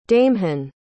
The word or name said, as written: daimhin